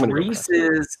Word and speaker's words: Reese's: Okay, first of all, it's Reese's. – Reese's. – It does not rhyme with feces.